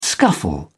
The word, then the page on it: scuffle: British English